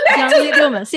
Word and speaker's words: John Lee Dumas: John Lee Dumas, yeah.